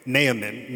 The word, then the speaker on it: Naaman: Very good pronunciation, by the way. Naaman.